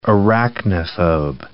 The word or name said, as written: arachnophobe